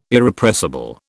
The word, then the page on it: Irrepressible: British English